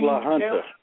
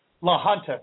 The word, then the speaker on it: La Junta: How do you say that last? The town name? – La Junta. – La Junta.